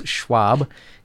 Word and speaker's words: Schwab: So Klaus Schwab, is that how I say his name? – Klaus Schwab.